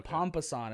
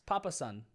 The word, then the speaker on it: papasan: You said pompasan. I think it was just papasan. – Yeah. – It's papasan.